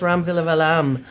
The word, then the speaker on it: Villivalam: So um, we're really lucky that you uh, deigned to come down here and talk to us, Ram Villivalam.